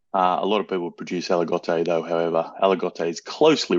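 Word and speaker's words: Aligoté: Spelt Aligote, pronounced Aligote, the only one of the three living varieties that are permitted in white Burgundy that include Chardonnay.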